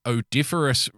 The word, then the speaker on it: odoriferous: odoriferous. No, you had it right the first time. Odoriferous is the correct pronunciation.